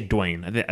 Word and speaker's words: Dwayne: Now, that one was controversial because some people said Dwayne is two syllables. I say Dwayne. I say that's one syllable.